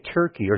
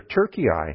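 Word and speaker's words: Türkiye: modern-day Turkey, or Turkey, as the modern pronunciation of that area is.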